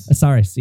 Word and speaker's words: Osiris: Osiris.